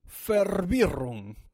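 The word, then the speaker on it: Verwirrung: Yo te Y hoy vamos a saber qué significa di Fervirrum, con ahí, con acento, bien acento fuerte suizo. Di Fervirrum, que se escribe V-E-R-W-I-R-R-U-N-G, y no es otra cosa que una confusión.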